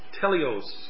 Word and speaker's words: teleios: Now, the word perfect here is the Greek word teleios, and it's spelled T-E-L-E-I-O-S.